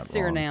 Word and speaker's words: Suriname: I'm positive I'm pronouncing that Suriname. – Wrong. Suriname, thank you.